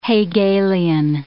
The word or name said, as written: Hegelian